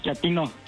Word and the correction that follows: chatino: ¿Chapino? – No, chatino. – Es como chato, ¿verdad? Chatino. – Sí, azunero, como chato, chatino.